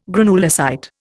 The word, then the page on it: granulocyte: Indian